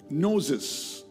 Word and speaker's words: gnosis: We know the Greek word for knowledge is gnosis, not Moses, G-N-O-S-I-S, but you pronounce gnosis.